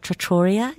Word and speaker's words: trattoria: And then where I was staying, we went walked down some alleyways, and the good old trattoria, is that how you pronounce it? – Yeah.